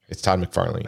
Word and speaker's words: Todd McFarlane: Todd McFairlane. – Cool. Well, no, I apologize, listener. – Look, it's spelled A-N-E. So it's Todd McFarlane.